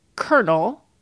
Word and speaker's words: colonel: We could do a radical example like colonel, spelled with an L, pronounced with an R.